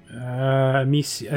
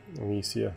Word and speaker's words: Amicia: your main character is Amicia. Is that how you pronounce it? – Amicia, I think it is. Yeah. – Amicia.